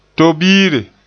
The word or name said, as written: tobiire